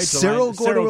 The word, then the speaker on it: Cerro Gordo: How do you pronounce this? Carroll Gordo, Illinois? – Cerro Gordo, Illinois, right near Decatur.